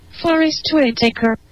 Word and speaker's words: Forrest Whitaker: Forrest Whitaker. – Ah, esa no se olvidó. Oye. – Forrest Whitaker. – Va, si no. – Forrest Whitaker. – Ese está peor. – Whitaker. Forrest Whitaker.